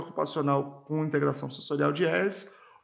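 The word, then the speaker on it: Ayres: E nós temos a integração sensorial de ERS, é, se escreve A-Y-R-E-S, é, que é implementada por terapeutas ocupacionais com essa formação e que é indicado para é, é desregulações sensoriais, né, desordens sensoriais.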